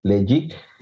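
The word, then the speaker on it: Ledaig: Y se escribe Ledaik para los que nos estén escuchando y se pronuncia o se pronuncian los pronuncian Legic.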